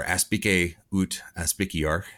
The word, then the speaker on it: aspice ut aspiciar: Um, uh, but uh, oh, and then the last one um, is aspid ut aspiciar, um, or aspice ut aspiciar. Got to get my hard C's in there.